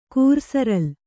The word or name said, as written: ಕೂರ್ಸರಲ್